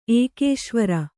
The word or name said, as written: ಏಕೇಶ್ವರ